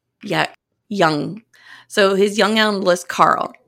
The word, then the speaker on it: Jung: So he decides to write his young analyst spelled J-U-N-G-I-A-N. It's pronounced young, like the word.